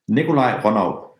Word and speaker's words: Nikolaj Rønnow: Nikolaj Ronnow.